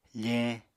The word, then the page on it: feñ: apatani